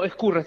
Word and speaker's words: Ascurra: No, Ascurra, está bien, Ascurra. Está bien como lo pronunciaste, Pati.